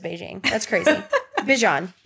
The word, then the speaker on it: Bijan: Not pronounced Beijing. – There's a TikTok of somebody asked him, they were like, what's the craziest way someone's ever pronounced your name? And he said Beijing.